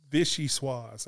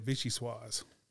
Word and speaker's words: vichyssoise: It's vichyssoise. That's how you say it, vichyssoise.